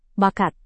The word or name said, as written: bakat